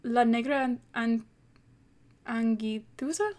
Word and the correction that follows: La Negra Angustias: Hold on, let me double check, because I feel like I butchered that pronunciation. It is called, referring to my notes, La Negra Angustias.